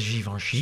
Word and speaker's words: Givenchy: je maakt ook nog eigen printenboeken: Valentijn en zijn viool en Voor Ouderie met Liefs, over modeontwerper Hubert de Givenchy, zeg ik dat goed? – Hubert de Givenchy.